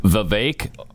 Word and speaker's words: Vivek: And they were told, Vivek like cake. – And so, nobody except them, and I've a few times when I venture out there on that limb, nobody pronounces it like that. Okay. Everybody says Vivek.